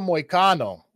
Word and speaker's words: Moicano: But Money Morricone, I guess, is that how you say his last name? – Morricone? Oh, Renato Moicano. Money Moicano. – That's what it is. Money Moicano. So I can't even say it when I know the pronunciation. – Moicano. – Moicano. I'm so Canadian.